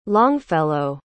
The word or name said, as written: longfellow